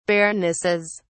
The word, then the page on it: baronesses: British English